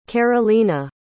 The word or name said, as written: karolina